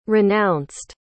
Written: renounced

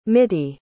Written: mittie

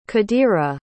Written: qadira

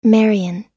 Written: marian

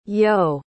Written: yoe

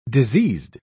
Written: diseased

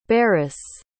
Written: barras

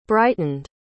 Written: brightened

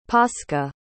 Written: pasca

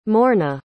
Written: morna

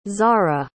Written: zahrah

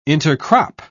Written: intercrop

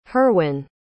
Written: herwin

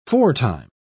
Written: foretime

